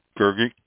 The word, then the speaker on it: Grgich: And the wineries include Donum Estates, D-O-N-U-M, Donum Estates, located in Canaris, Gergich Hills, always have problem pronouncing that, G-R-G-I-C-H, Gergich, I guess.